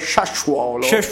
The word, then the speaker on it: Sassuolo: È di Sassuolo, perché la pronuncia esatta è Sassuolo.